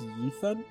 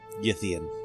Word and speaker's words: Yithian: Oh, she tells you she's a, I think it's pronounced Yithan? – Yithian.